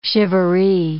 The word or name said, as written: charivari